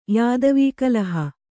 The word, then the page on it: ಯಾದವೀಕಲಹ: kannada